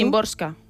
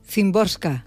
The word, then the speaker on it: Szymborska: A ver, ¿cómo lo pronuncias tú? – Szymborska. – Szymborska. – Entiendo yo que sí, por lo como yo he escuchado a la gente pronunciarlo.